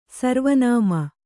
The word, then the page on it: ಸರ್ವನಾಮ: sarva nāma